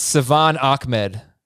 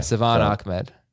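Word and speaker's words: Salvon Ahmed: Okay, yeah, we butchered it. It's Savon Ahmed. – Yeah, that's the way they say it. – There you go, yeah. – Savon Ahmed.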